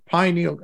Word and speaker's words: pineal: Pineal, how do you say, what's the proper pronunciation? – Pineal.